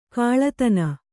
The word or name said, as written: ಕೀಳುದನಿ